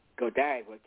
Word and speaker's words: Godiva: And how women react over a box of chocolate, especially I hope I pronounced that word, Godiva candy, G-O-D-I-V. – You got it.